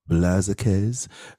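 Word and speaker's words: Blázquez: Did I say that right? Blaziquez. – Sounds right to me.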